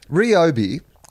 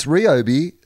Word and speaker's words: Ryobi: Ryobi is how it's pronounced. – Uh, Podcast okay. Mike has put that uh, up on the little message uh, window. And it says, it's Ryobi, source, I can read Japanese.